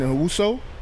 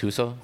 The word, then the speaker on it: Hueso: Also, shouts to Freddie Prince and Uso. I want to say, am I saying his name right? Uso? H-U-E-S-O? Uso? – I think it's Huso.